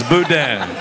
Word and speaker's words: boudin: I think that's better than y'all's Bodine. – It's Boudin. Boudin. – And he said it wrong.